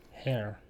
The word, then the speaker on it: Jair: It's J-A-I-R. Hair? – Jair. – Jair? – Jair? – But Jared, it's South America though. J makes a huh sound. – Hair.